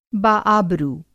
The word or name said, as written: बाआबरू